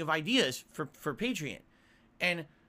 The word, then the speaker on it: Patreon: It should be Patreon, like you're a patron of the arts, Patreon. Yet fucking most people pronounce it Patreon.